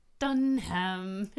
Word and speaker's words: Dunham: Dunham.